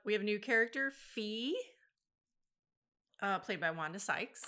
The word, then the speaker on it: Phee: How do you spell that? – P-H-E-E. – I was going to say, because Fi is a... – Yeah. Well, some people say Fee instead of Fi.